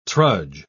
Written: trudge

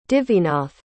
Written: devinath

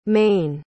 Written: mayne

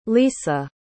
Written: lysa

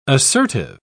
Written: assertive

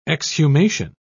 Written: exhumation

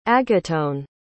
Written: agatone

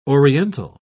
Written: oriental

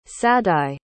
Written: sadye

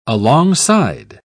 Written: alongside

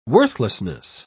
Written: worthlessness

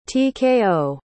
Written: tko

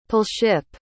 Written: palship

